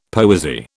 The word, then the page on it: poesy: British English